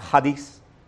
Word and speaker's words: charis: And the word is pronounced hadis. Hadis. C-H-A-R-I-S, if we were to transliterate it.